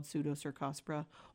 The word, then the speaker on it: Pseudocercospora: It's called Pseudocercospora. Oh man, I said it right. – You said it perfect.